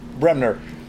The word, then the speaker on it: Bremner: Bremner. – Bremner. Bremner. Okay. – B-R-E-M-N-E-R.